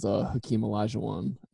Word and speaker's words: Olajuwon: I think the only other person was Hakeem Olajuwon, if I said that correctly.